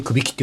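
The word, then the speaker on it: 頸城: これ、くびきってのは、久しいに比べる岐阜県の義、うん、まあ、分かれるという意味だと思うんですけど、くび、これでくびきって読むんですね。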